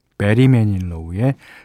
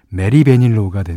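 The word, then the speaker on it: Barry Manilow: 이 베리 매닐로우를 발음하기가 참 힘듭니다. 예. 어, 자칫 잘못하면 메리 베닐로가 우 되니까 조심하시기 바랍니다. 빌보드 핫백 차트 26위까지 기록했고요.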